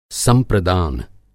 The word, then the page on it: संप्रदान: Hindi